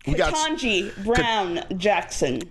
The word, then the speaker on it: Ketanji Brown Jackson: Ketanji Brown Jackson.